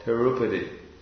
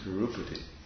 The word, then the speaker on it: Tirupati: It's Tirupati. Is it Tirupati? They pronounce it Tirupati. But why is it pronounced Tirupati? There's no long A. It's a short A. And if it was short U, Tirupati.